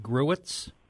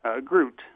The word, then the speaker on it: Gruit: Is it Gruits? – Groot. – Groot. – Some people might say Gruit, but I say Groot.